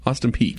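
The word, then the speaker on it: Austin Peay: Is that Austin, how do you say that? Austin P? P-E-A-Y. – Yeah. – Austin P.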